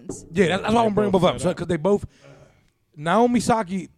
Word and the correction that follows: Osaka: Osaka. Is that how you say yes. – it? – Yes. – Thanks, John Sanders. John Sanders said Naomi Osaka.